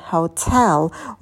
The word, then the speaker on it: hotel: И гостиница – это hotel. Ударение также падает на второй слог, как и в русском языке.